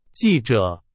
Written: 记者

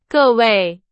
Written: 各位

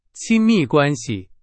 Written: 亲密

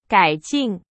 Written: 改进

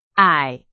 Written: 矮

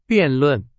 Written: 辩论

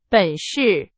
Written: 本事

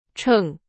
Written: 城

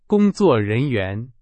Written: 人员

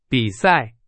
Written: 比赛

